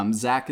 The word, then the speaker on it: Zack: Zach is spelled Z-A-C-K.